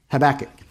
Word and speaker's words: Habakkuk: If you turn over to Habakkuk, am I saying that right? Habakkuk, that's it, there you go.